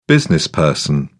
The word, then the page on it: businessperson: British English